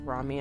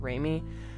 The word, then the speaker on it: Rami: I've already made the mistake that I made repeatedly during Rami Malek's recording, which is that his name is actually pronounced Rami and not Rami.